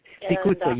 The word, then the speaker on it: Siracusa: Siracusa, yeah, beautiful mountain village.